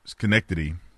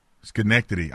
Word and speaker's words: Schenectady: So I want to give a special shout out to Amsterdam Schenectady. Am I saying it right? – Schenectady. – I knew I had it right. Amsterdam Schenectady.